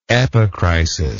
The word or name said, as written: epicrisis